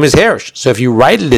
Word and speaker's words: Hirsch: That's the way people pronounce it, Hirsch. He says with a segol, but that's not relevant to us.